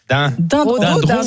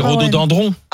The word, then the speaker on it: rhododendron: Attends, deux secondes, parce qu'on dit rhododendron. – Dindron. – C'est piégeant. – C'est dendron. Alors c'est rhododendron. – Ah bon, oui. – Est-ce qu'on y a... Ça s'écrit dendron.